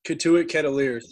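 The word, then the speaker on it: Cotuit Kettleers: Is it the Kettleers? Did I say it right? – Yes, Kituit Kettleers.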